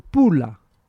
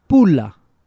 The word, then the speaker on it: Pula: It's called Pula. – Huh? – Pula. – Spell it? – P-U-L-A.